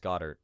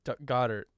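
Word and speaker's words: Goedert: Goddard? – Dallas Goddard. – Goddard.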